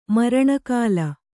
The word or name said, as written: ಮರಣಗಾಲ